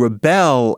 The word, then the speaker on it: rebel: Rebel, accent on the first syllable, is a noun.